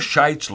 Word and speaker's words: Scheidt's: Mr. Scheidt's last name is spelled S-C-H-E-I-D-T, but it's pronounced the same as the old English form of a word I'm not allowed to say on the radio.